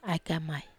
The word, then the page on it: ai kamai: Wamesa